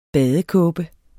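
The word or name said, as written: badekåbe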